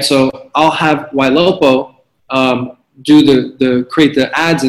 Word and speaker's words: Ylopo: Ylopo, Y-L-O-P-O.